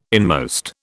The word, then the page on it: inmost: British English